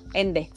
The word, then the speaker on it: Ende: Y después como que mis papás yo creo que le dieron en el clavo porque leí El Principito y leí a Michael Ende, creo que no se pronuncia así, ¿no? Eh, bueno, Ende.